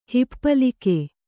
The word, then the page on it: ಹಿಪ್ಪಲಿಕೆ: hippalike